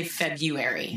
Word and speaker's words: February: Now, I will tell you that I do say February. Good. I do not pronounce both R's in the word February.